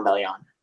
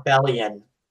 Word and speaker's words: Bellion: I was momentarily taken aback by the mispronunciation of his last name. But... – Sorry, John Bellion. Yes, it's John Bellion.